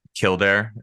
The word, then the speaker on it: Kildare: And Kildare, is that how you say it? – Yeah.